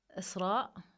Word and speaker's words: Isra: To be honest, I'm still on a journey, but I can say that Isra, this is like how you say my name in Arabic.